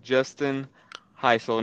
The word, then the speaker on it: Justin Hisle: We are joined by East Jessamine quarterback Justin Hisle.